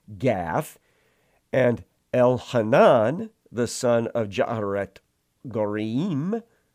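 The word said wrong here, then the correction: Jaare-oregim: I butchered that, sorry, Jaaret Oregim, the Bethlehemite, struck down, it's the brother of Goliath the Gittite.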